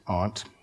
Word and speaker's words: aunt: I have an aunt, um, and that, that is the proper way to pronounce it, aunt.